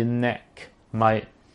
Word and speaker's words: neck mic: 咁但冇識問我咩叫 net mic，但係我就可能我讀得我發音唔好啦，係 neck 即係頸嘅 mic，即係呢支為之 neck mic。咁 neck mic 咧就勝在咧。就近距離啦，咁啊收唔好到雜聲啦，因為我針對喺街度咧太嘈嘅話，我又唔可以太大聲講嘢咧，咁用 neck mic 咧就可以令到成件事冇咁尷尬啦。